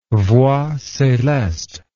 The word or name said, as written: voix celeste